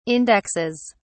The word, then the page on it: indexes: British English